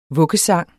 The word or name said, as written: vuggesang